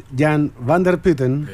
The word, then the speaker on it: Jan van der Putten: No, no, no, Estamos con el colega neerlandés Jan van der Putten.